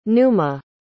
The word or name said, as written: numa